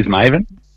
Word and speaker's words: Mavin: Yeah, it is Mavin. But Mavin, it's all right.